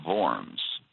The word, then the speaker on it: Worms: So they agree to meet together in Worms. In German, the W is pronounced like a V. It's Worms, but many people in America just call it the Diet of Worms.